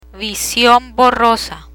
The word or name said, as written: vision borrosa